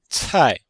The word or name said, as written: cai4